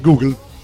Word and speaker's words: Google: Apple e anche sugli store di Google.